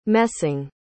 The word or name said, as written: messing